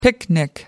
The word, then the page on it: picnic: American English